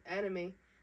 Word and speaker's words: Anayme: And so when I tell people that are, like, white, that I tell them it's anime, oh, my gosh, like, the cartoons? Yeah. And I'm like, no. They're not, like, the cartoons. Like, not at all. And you don't even spell it the same.